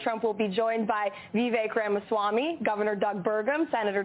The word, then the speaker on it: Vivek: If they want to be vice president, they're going to have to learn how to say his name correctly. It's Vivek.